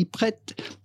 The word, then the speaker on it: Pret: This sandwich shop chain based in the United Kingdom, popularly referred to as simply Pret. So how would you go about pronouncing this phrase that literally comes from French meaning ready to eat? Ready, Pret, to eat, manger in French.